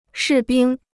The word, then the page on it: 士兵: shì bīng